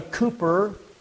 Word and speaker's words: Cowper: And it's written by Brother Cooper, except we pronounce his name, or it looks like Cowper, but William Cooper.